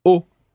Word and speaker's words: eau: The word for water in French is eau. – Eau. – It's spelled E-A-U, O.